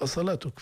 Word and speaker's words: أصلاتك: حتى الكتابة ننتبه مثلا أصلاتك هي تنطق أصلاتك في روايتنا وإن كانت هنالك واو لكن هذه الواو فوقها ألف صغيرة تعني أنها بدل من الواو أصلاتك.